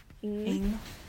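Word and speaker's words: Ng: Ing. – Celeste Ing. – Ing.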